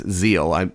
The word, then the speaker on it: Seel: And so, I pronounced the German wrong, it's Zeal, S's are Z's.